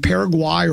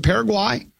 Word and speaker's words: Paraguay: Is it Paraguay or is it Paraguay? – I like saying Paraguay, but I think they say Paraguay.